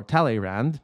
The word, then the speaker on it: Talleyrand: Well, I have some other words to say to put in his government, and I asked people how to pronounce this properly by the words of immortal Talleyrand, or Talleyrand. I try to make it kind of sound properly French because this needs to be said in French.